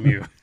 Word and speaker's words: Lemieux: I might say his name wrong. Mario Lemix? – We're all laughing here, but yeah, I'll give it to you. Mario Lemieux.